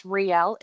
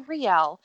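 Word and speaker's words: Rielle: When we follow, I think her name is pronounced Riel. It's R-I-E-L-L-E.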